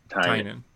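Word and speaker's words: Tynion: Yeah, except for Tiny Ian wasn't that big because he was just focusing on Batman and then Nice House and Joker. – Tynan. The correct pronunciation is Tynan.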